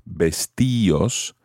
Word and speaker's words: vestíos: the D comes off and you have vestíos. However, you have to add an accent to the I in order to keep that type of pronunciation.